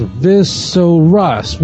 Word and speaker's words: visoras: So here we have the word, actually there's no dot in the tough, so it's pronounced visoras, which means, and the teachings of.